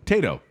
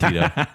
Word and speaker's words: Tayto: Tayto? T-A-Y-T-O? Tato? – Tato. – Oh, Tato. – I call it Tito. – Yeah, I've been saying it wrong this whole time.